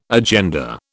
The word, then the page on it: agenda: British English